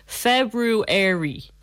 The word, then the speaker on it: February: Okay, so many people have trouble pronouncing the English word which has two or sounds close together. So make sure to pronounce both or sounds so this is how they've broken it down. February.